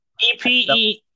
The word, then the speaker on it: Ephesians: Okay, I have a prayer from the book of Patience. I don't know how to say that, but am I saying it right? – Ephesians. Oh, the book of Ephesians.